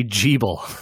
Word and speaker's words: Gebel: it is spelled capital G-E-B-E-L. – G-E-B-E-L. Gebel. – Yeah. Nope. They say Jebel in the English dub, I suppose.